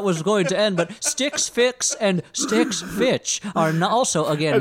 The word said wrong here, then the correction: Stitch Fix: Stitch Fix.